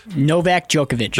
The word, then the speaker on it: Novak Djokovic: Novak Djokovic.